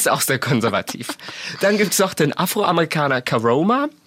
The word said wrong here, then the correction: Karamo: Heißt er so? Caramo. – Caramo. – Karamo.